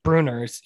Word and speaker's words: Bruener: Is it Briner? Is that how you say it? or Bruener? – It's Bruener's. He's Mark Bruener's son, the tight end from the early 90s.